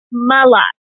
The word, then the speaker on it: Mlott: My name is Jennifer Mlott, like a lot, but with an M, Mlott.